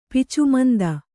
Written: ಪಿಚುಮಂದ

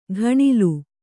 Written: ಘಣಲು

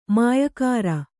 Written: ಮಾಯಕಾಱ

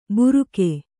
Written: ಬುರುಕೆ

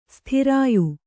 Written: ಸ್ಥಿರಾಯು

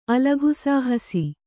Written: ಅಲಘುಸಾಹಸಿ

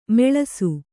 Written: ಮುಳಿಸು

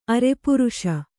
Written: ಅರೆಪುರುಷ

